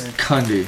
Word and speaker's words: Cundey: I mean, the movie, the cinematographer, is it pronounced Cundey or Cundey, by the way? – I think it's Cundey.